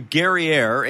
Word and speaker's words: Guerriere: Well, our next guest is Marcia Guerriere, and she said, just pronounce it like it's Perrier.